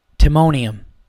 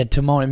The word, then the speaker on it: Timonium: So now after this, I go down to Magoobies Comedy Club in, how do you say the town? It's like literally right on the border of Baltimore. It's Timonium. Yeah, Timonium.